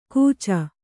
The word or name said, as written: ಕೂಚ